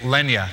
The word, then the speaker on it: Lenya: I'm married to a wonderful woman named Lenya. It's pronounced like Kenya, the country, with an L.